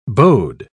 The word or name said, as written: bode